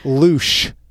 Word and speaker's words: louche: word of the week this week, don't laugh, is louche. Louche. Yes. Yes. Spelled like douche. – Wow. – Louche. L-O-U-C-H-E.